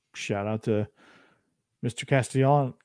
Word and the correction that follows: Castellanos: Castellano. Castellanos. There you go.